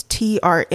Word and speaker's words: Trafalgar: Trafalgar Square, Trafalgar. It's Trafalgar. Lots of A's.